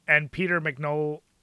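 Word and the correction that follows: MacNicol: was it? How do you say his name? The guy from Ghostbusters 2. The guy who played Renfield in that movie. Peter McNichol. He was a good version of what you're trying to describe.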